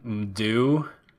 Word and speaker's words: Mdou: It's like Mdou Moctar, M D O U. So it's like Mdu.